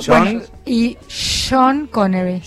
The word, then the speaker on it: Sean: Se escribe Sean y sí. Se dice Sean. Pero cuando es Lennon, es John Lennon.